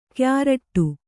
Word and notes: ಕ್ಯಾರಟ್ಟು: kyāraṭṭu

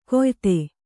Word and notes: ಕೊಯ್ತೆ: Kannada

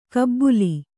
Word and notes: ಕಬ್ಬುಲಿ: kabbuli

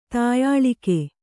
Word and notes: ತಾಯಾಳಿಕೆ: tāyāḷike

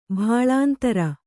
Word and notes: ಭಾಳಾಂತರ: kannada

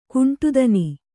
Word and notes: ಕೊಂಡಿತನ: koṇḍitana

